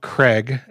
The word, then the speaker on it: Kreg: Actually, two amazing things from a company called Craig. It's spelled kind of strangely, K-R-E-G, but they make a pocket hole jig.